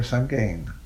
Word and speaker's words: Sagane: Sagane or Sagane? Sagane. – Sagane. – Sagane. – S-A-G-A-N-E. – S-A-G-A-N-E.